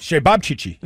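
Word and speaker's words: Ćevapčići: Shebabchichi. Yes, okay. It's C-E-V-A-P-C-I-C-I. And it's sort of a Serbian or a Croatian sausage. It was delicious, by the way. Shebabchichi.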